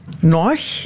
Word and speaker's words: Noig: Dat is Noig. – Ja. – Zeg ik dat goed? Uh, je zegt dat zeer goed.